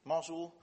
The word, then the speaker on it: Mosul: I don't know if you have read the news about Mosul, how do you pronounce it in English? Mosul.